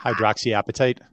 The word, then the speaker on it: hydroxyapatite: And I'm getting served a ton of ads for gum that has things like xylitol or I'm going to totally butcher this, hydro. – Hydroxyapatite. – Yes, hydroxyapatite.